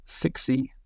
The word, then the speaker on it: Cixi: Another one, Julia, actually, from when I was at Eurobike, I got to meet a company called, I think you pronounce it, Sexy. CIXI.